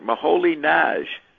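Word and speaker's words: Moholy-Nagy: You know, that's how they pronounce it. I was told this in New York. Moholy Nagy. It's not Nagy.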